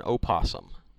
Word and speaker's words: opossum: Those and possum and opossum. – Sure. – I guess. – Yeah, because the O is silent, according to you.